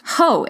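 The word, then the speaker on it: Hoh: Ho, H-O-H. I am saying that properly.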